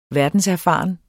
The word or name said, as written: verdenserfaren